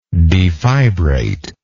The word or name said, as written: defibrate